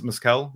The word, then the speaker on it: mezcal: So is it mezcal? Am I pronouncing it right? – Sure. That sounds good to me.